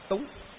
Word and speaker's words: túng: Chữ nguyên văn còn nói chữ túng tham sân si, đọc là túng.